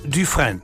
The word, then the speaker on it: Dufresne: Und der schreibt sich folgendermaßen: D-U-F-R-E-S-N-E. Etwa ausgesprochen Dufresne. Dufresne, etwa so.